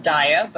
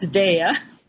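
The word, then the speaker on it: Daiya: I know some people call it Daya, but I've been told it's pronounced Daya, like dairy, Daya.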